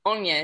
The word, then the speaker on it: Aine: It's just my name, which is Anya Rose Daily. Anya is spelt A-I-N-E, and then Rose, and then Daily, Daly.